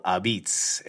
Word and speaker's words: apizza: It is not called pizza. As Ben said before, it is called Abiz, and it's spelled A-P-I-Z-Z-A, which is an interesting little tidbit.